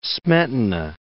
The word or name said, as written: Smetana